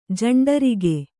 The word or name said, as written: ಜಂಡರಿಗೆ